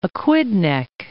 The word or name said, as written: Aquidneck Island